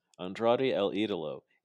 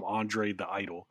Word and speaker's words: Andrade El Idolo: Yeah, yeah, yeah, Don't call him Andre the Idol this time.